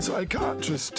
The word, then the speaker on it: psychiatrist: And psychiatrist takes a silent P.